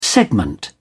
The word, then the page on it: segment: British English